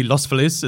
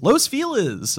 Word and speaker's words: Los Feliz: what should be Los Feliz is Los Feliz.